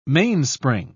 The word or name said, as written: mainspring